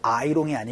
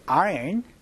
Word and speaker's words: iron: 이거 아이롱이 아니고 아이언이라고 발음해 주셔야 됩니다. 연습 한번 해보도록 할게요. 갑시다. 시작. I don't know how to iron clothes.